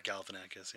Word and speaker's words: Galifianakis: I like Galifianakis. – Mm-hmm. – Or however you say his name. – Zach Galifianakis, yeah. – Galifianakis.